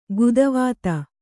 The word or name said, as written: ಗೀತಿವಿದ